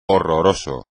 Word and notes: horroroso: espagnol

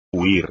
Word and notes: huir: espagnol

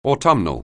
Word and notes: autumnal: anglais

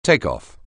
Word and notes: takeoff: anglais

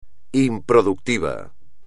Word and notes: improductivo: espagnol

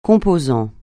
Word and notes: composant: francais

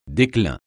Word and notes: déclin: French

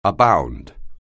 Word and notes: abound: anglais

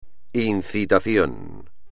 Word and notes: incitación: espagnol

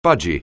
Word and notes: budgie: anglais

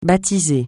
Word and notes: baptiser: francais

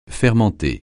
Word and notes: fermenter: francais

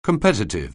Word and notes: competitive: anglais

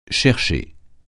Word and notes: chercher: français

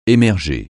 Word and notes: émerger: français

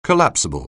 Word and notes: collapsible: anglais

